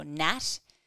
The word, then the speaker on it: NAT: So nucleic acid amplification test, or NAT, it does rhyme with RAT, but they're very different things.